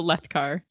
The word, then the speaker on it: Alethkar: I'm Alethkar, second syllable.